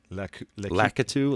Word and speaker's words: Lakitu: It is where we first find, how do you say this guy's name? – Lakitu? Yeah. That's how I say it.